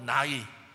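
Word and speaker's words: 나이 (ναί): 이 예는 헬라어로 나이, 뭐 우리 먹는 나이하고 발음이 똑같습니다. 나이로서 옳다, 진실로, 확실히 라는 뜻을 가지고 있습니다.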